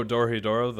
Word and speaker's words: Dorohedoro: The next thing we are watching is Dorondero. Am I saying that right? – Oh, Dorohedoro, the anime, right? – Yes.